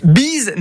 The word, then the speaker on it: Beez: Biz n'a rien à voir avec la bise. Biz s'écrit B-E-E-Z comme Freeze.